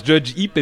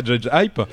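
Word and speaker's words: JudgeHype: qu'on prononce Judge Heap et pas Judge Hype,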